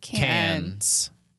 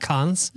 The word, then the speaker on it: Cannes: It happens in, I always say it wrong. – Cannes. France. – Cannes, France. – Not cons. – Cons? – No.